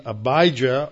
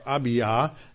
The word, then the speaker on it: Abijah: And the second name, Abijah or Abiyah, Yah, J-A-H, is the first syllable in Yahweh.